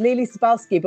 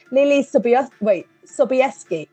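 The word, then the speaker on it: Leelee Sobieski: Lily, right, I've always pronounced this Lily Sobalski, but when I was doing my interviews, right, not my interviews, I was listening to interviews, apparently it's pronounced Lily Sobieski.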